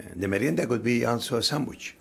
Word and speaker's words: merienda: How do I say that? Merienda? – Merienda. – Oh, I will say that.